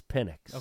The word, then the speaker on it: Penix: Is it pronounced Penix or Penix? – We just ordered shirts, though. – It's Penix.